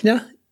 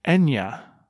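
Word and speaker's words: Eithne: It's Ethna. Ethna. – It's actually pronounced Enya. – Oh. Are we sure? – I could be wrong about that. I think it's pretty close. – Yeah. I think they read, said Anglicized? No, that's just... – It's an anglicized spelling of the actual pronunciation.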